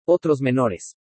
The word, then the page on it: OTROS MENORES: spanish